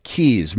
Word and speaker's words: Quays: We were talking about how to pronounce Q-U-A-Y-S. – Or just A-Y. – The Keys restaurant, spelled Q-U-A-Y-S restaurant in Galway, Ireland, is pronounced Keys.